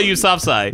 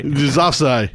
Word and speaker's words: Yousafzai: Malala Yousafzai. – Yousafzai.